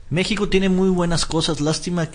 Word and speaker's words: México: México debe estar escrito con acento y pronunciar la X como J.